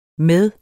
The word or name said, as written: med